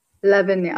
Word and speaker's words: Lavinia: Lavinia.